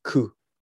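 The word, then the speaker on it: ku (九): And nine is ku, you know it's pronounced as ku q or ku but a ku is suffering mm-hmm. – the same as suffering so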